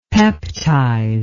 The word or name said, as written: peptize